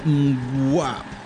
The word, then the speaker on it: Mgła: Volvemos a Polonia y ahora vamos a presentar a Mgla, pronunciado Mgła, significa niebla en polaco.